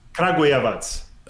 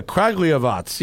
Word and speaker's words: Kragujevac: Do you know about the legendary Kragujevac? – Okay. – Kragujevac? – Kragujevac. – Oh, there you go. Yeah, Kragujevac.